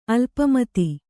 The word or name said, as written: ಅಲ್ಪಮತಿ